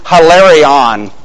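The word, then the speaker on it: hilarion (ἱλαρόν): because the word in the original is hilarion. Do you recognize our English word hilarious from that?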